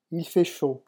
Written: il fait chaud

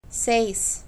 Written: seis

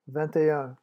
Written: vignt et un

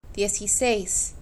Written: dieziseis

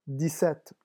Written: dix sept

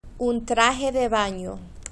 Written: un traje de bano

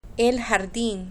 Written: el jardin

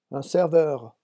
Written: un serveur

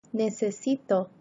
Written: necesito

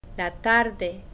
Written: la tarde

